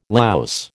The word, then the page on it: louse: American English